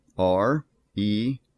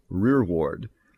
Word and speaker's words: rereward: It is spelled R-E-R-E-W-A-R-D, which looks like re-reward, but actually pronounced rearward.